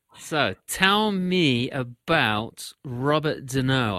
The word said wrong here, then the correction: Desnos: I hope I'm pronouncing that right. – Desnos. Rob- Des- oh, it is Desnos.